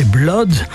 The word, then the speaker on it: blood: Bref, ces 4 exceptions se prononcent soit comme cut ou mud, ce sont le sang, blood, et l'inondation, flood.